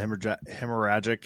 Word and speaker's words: hemorrhagic: So let's talk about EHD, the hemorrhagic. Did I say that right? – Yeah. – Oh, look at me, Mike, coming for your job. I can pronounce hemorrhagic now.